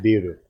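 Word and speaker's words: biru (ビール): Beer is pronounced biru. – Biru. – That's what you mean, biru.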